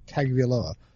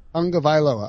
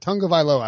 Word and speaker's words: Tagovailoa: Let's get, and I find it fascinating, the idea that Tua Tagovailoa. – Tagovailoa. – All right. Now I'm good. Now that I got that, Tagovailoa, I won't mispronounce it again.